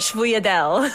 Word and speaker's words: Sfogliatelle: Yes, Sfogliatelle. Yeah. – It's my Italian. I'm originally from Long Island, New York, and nice. They call it Sfogliatelle. That's how they say it.